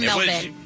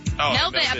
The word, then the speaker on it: Melbourne: It's not Melbourne, it's Melbourne. Or is it Melbourne? – Melbourne. Or Melbourne. Melbourne. Apparently they don't pronounce the R. – Okay. So no R's.